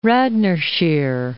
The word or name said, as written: Radnorshire